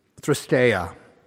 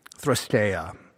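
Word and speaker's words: threskeia: The next word that I want to look at is thrusteia. Thrusteia.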